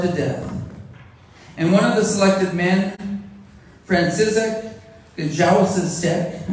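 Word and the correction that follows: Gajowniczek: I said that wrong too, Kajawamizek, cried out, he said, My wife, my children, I shall never see them again.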